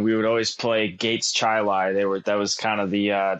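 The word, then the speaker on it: Chili: First of all, how far do you actually live from Chi-Lai, New York, and is it pronounced that way? – It is pronounced Chi-Lai, and I'm about 20 minutes away.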